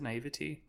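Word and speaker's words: naïveté: That's where that came through, was where we still had this naivety. Is that how you pronounce it? Naivete? Where we still had this. – I think both work.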